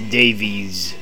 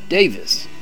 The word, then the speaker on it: Davies: For the longest time, I pronounced the last name as Davies, as most American fans probably have, but in their native England, it is Davis.